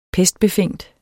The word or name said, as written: pestbefængt